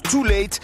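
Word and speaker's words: Tul8te: Son nom s'écrit de manière stylisée avec un U et un 8, mais ça se prononce Too Late.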